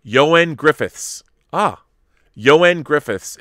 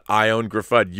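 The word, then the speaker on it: Ioan Gruffudd: Mr. Fantastic's Sock says, Yoen Griffiths is the proper way to say Yoen Griffiths. I didn't know that. Yoen Griffiths. All right. Not Griffud. Not I own Griffud. Yoen Griffiths. That's good to know. Yoen. Kind of like Saoirse, as in inertia.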